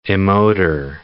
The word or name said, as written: emoter